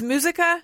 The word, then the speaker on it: Muzyka: It's Muzyka? – Muzyka.